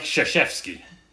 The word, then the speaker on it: Krzyzewski: Duke University basketball coach Mike Krzyzewski.